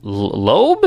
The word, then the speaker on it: Loeb: we're going to be doing Batman Hush, which is a comic book series in the early 2000s by Jeff Loeb.